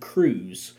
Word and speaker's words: krewes: So, like I said, they have floats, they have all kinds of stuff, and they have what is called crews. These crews are the people that walk in the parade, that help design the floats. It's spelled K-R-E-W-E-S.